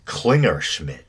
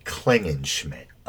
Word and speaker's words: Klingenschmitt: Well, anyway, I pronounced his name incorrectly as Klingerschmitt, but I guess it's actually Klingenschmitt.